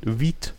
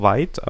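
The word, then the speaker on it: Vite: Ja, denn aus der Frontend-Welt mal was, und zwar ist da von Viet, manche sagen auch weit, aber es wird Französisch ausgesprochen, weil auch der Schöpfer Französisch ist.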